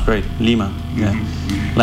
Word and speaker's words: Lima: Yeah, it's the same. A little longer on the I. Lima.